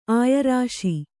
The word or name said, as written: ಆಯರಾಶಿ